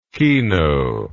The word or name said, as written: kino